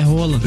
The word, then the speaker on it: Haaland: Desculpa, Haaland. Desculpa, Rola. – Que isso? – Não é o nome dele, é Rola. – Na pronúncia norueguesa.